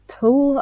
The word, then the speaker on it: tulle: But yeah, it's ivory and it's got I think it's called Tulle, I can't pronounce it. T-U-L-L-E. Yeah, tulle. – T-U-L-L-E.